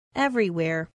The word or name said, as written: everywhere